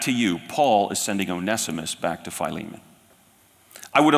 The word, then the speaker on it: Onesimus: That's how you pronounce it, Onesimus. Some of you may pronounce it Onesimus. If you do, then you're probably from England.